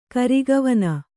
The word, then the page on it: ಕರಿಗವನ: kannada